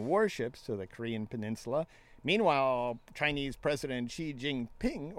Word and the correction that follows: Xi Jinping: or I saw that, said that wrong, Xi Jinping, told Trump that China will work with the U.S.